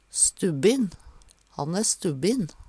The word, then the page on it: stubbin: Numedalsmål